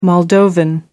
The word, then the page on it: moldovan: American English